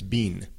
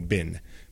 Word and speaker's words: been: Pero en los Estados Unidos nosotros simplemente lo decimos como bin, como si fuera B-I-N, pero es B-E-E-N. Okay, we say it like it's B-I-N, but it's actually B-E-E-N.